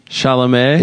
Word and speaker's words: Chalamet: I haven't seen it yet, but I feel like it's you don't deserve those kind of sweet dickings that kid, that Timothy Chalamet got. – Timothy Charlemagne the God. – Shyamalan. – Yeah. – It sounds like Timothy Shamwow Malay. – It sounds like he's pronouncing it wrong. – Chalamet.